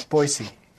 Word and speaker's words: Boise: The locals have a special way of pronouncing it, and they tease all the visitors. – They do, actually. If you say Boise, they get real, like... – You got it. It's Boise. – Boise.